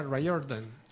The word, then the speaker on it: Riordan: Se escribe Riordan, pero se pronuncia Riordan.